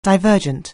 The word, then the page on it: divergent: anglais